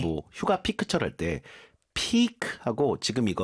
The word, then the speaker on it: piqued: A few years ago, I saw a coworker solve a 3x3x3 cube at the office, and it piqued my interest. – Piqued. – Piqued가 아니라 piqued예요.